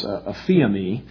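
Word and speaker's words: aphiemi (ἀφίημι): The primary Greek verb that we find in the New Testament is a word that's pronounced aphiemi, but it has the idea of sending something away or releasing something.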